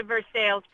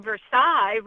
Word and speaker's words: Versailles: They're pronounced Versailles mainly because of the early Scots-Irish settlers. The Scots had a tendency to overemphasize the second syllable and not the first.